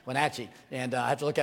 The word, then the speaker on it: Wenatchee: Is it Wachahatchee? How do you say that? Wenatchee. Wachahatchee-natchee. Wenatchee. Wenatchee.